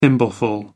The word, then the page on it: thimbleful: American English